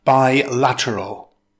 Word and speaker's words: bilateral: It consists of four syllables. Stress the second syllable. It's pronounced bilateral.